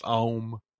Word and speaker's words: Aum: But in the research, it was A-H-O-M. But you're probably right. It was probably pronounced Aum.